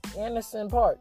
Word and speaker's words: Anderson Paak: Because to me, the only people that made a group that was smart was Bruno Mars and Anderson Park. Anderson Paak. I said his name wrong the first time.